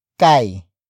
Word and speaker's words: kay: And then the next word is kai. Kai. Kai. Kai is spelled as K-A-Y.